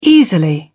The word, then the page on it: easily: British English